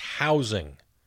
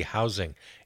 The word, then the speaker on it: housing: It's pronounced housing with a soft S, as in Z, housing. It's not housing.